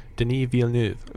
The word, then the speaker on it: Denis Villeneuve: I'm terrible at pronouncing his name. He is French. Will you help me out here? – Denis Villeneuve. – Villeneuve, thank you so much.